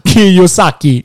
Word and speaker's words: Kiyosaki: Han heter Robert Kiyosaki.